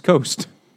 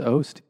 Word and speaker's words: Oast: This is a 5% alcohol premium blonde ale from Charleston, South Carolina, from the Edmunds Oast Brewing, not Edmunds Coast. – Or Oats. – I could fucking spell. – It's Oast.